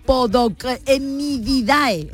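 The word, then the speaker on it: Podocnemididae: a ver si lo digo bien, de la familia Podocnidae. – Lo ha dicho perfectamente.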